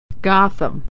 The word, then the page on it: Gotham: American English